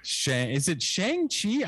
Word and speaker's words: Shang-Chi: And then you're going to do Shang-Chi? I haven't even seen it. I feel really bad. – It's pronounced like Shang. Shang-Chi, come on, Tony.